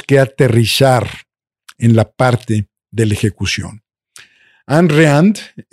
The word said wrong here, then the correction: Ayn Rand: eh, Ayn Rand, mejor pronunciado, una filósofa eh, en paz descanse maravillosa, tenía una frase bellísima.